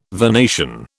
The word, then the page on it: vernation: British English